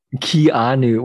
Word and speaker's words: Keanu: So the choice for this movie with the superstar Keanu, is that how you say it? – Keanu Reeves. – How do you say it again? – Keanu.